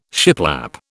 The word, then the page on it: shiplap: British English